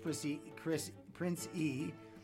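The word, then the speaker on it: Prince Ea: It's pronounced Prince E. It's spelled Prince E A, just in case you want to check him out.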